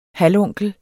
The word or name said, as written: halvonkel